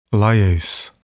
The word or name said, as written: lyase